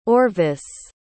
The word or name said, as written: orvis